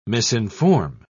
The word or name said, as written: misinform